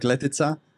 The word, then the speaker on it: Letica: So my name is, you said it right, Patrick Letica.